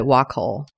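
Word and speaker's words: Wacoal: Is that how you say it? – That's how I've always said it, Wacol.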